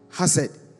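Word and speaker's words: hased (חסד): The Hebrew word for loyalty is called hased. Hased. H-E-S-E-D. H-E-S-E-D. But it's pronounced hased.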